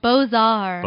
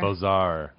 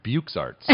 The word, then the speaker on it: beaux-arts: What was it? – Beaux-arts. – Beaux-arts. – Yes. – I would have pronounced it Bukes Arts.